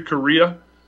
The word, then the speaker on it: Correia: It's called In Defense of the Second Amendment by Larry Correia. I think that's how you pronounce his last name, Correia.